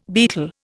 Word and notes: betel: Indian

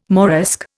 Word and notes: Moresque: Indian

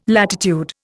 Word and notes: latitude: Indian

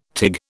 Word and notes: tig: British English

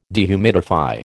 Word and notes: dehumidify: American English

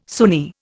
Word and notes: Sunni: Indian

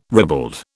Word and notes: ribald: British English

